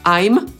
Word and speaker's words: I'm: I teoretycznie powinniśmy to wymawiać jako I'm, ale tak naprawdę częściej spotkamy się po prostu z am. I tak śmiało na co dzień możecie mówić.